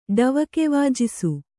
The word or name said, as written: ಡವಕೆವಾಜಿಸು